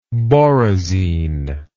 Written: borazine

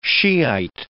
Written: Shiite

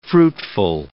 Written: fruitful

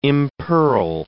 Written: impearl

